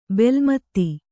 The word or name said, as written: ಬೆಲ್ಮತ್ತಿ